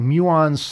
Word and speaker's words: muon: And the website, the compact, how do you say this word? M-U-O-N? – Muon.